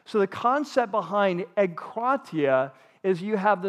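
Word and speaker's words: egkratia (ἐγκράτεια): The word is called egkratia. And so E-G, in English, E-G. And then K-R-A, and that's where the accent is on the second syllable, egkratia. And then it's T-E-I-A, egkratia.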